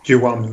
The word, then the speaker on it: Kiwami: Το, δεν ξέρω αν το προφέρω σωστά. Κιουάμι, καλά το λέω. – Κιουάμι, ναι, σωστά. Kiwami.